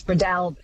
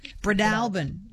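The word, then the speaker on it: Breadalbane: David in Tamworth says, I always understood it to be Bradolban with the emphasis on the second syllable, the third almost not pronounced, which is such an Aussie way of doing it. Bradolban, do you think, does that sound good? – Close, more A on the AHS, Bradolban. – Bradolban.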